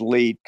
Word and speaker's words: leet: But yeah, it's 1337, which is an internet meme, which you can pronounce as LEET, because if you look at the numerals, it kind of looks that way.